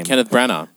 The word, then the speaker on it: Kenneth Branagh: Kenneth Branagh.